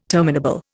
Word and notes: terminable: Indian

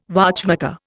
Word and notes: watchmaker: Indian

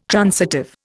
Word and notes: transitive: Indian

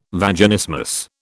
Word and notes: Vaginismus: British English

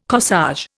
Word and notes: corsage: Indian

